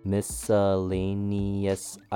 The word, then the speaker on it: miscellaneous: For the first word, we have miscellaneous, five syllables. Miss sounds like the miss in I miss you. S has a short U sound with an S in the front. Lay has a long A sound. Ni has a long E sound. And is sounds like the word is.